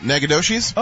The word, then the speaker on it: Nacogdoches: Oh, is it Nacogdoches? – Oh, there you go. – Is it New Mexico? – Texas, it says. – Texas? – It might be. – Yeah. I got it. – Nacogdoches.